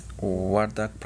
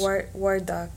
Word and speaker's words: Wardak: Wardak Province. – Wardak, yeah.